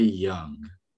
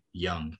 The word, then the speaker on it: Jung: It might be Young. Not Jung? Well, it's spelled Jung, but I think it might say it Young.